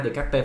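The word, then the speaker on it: Descartes: ok, Long Cao ơi, hồi nãy mình đọc sai cái tên của Decarte ha. Decarte phải đọc là Decarte, Decarte ha, Decarte, Decarte, Socrates, Socrates, Socrates, Socrates.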